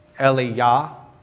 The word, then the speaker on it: Elijah: Elijah's name, Elijah's name is spelled E-L-I-J-A-H. Hebrew didn't have a J, that's a Y in Hebrew. Eliyah in Hebrew.